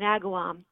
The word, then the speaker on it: Agawam: Agawam, you got it right.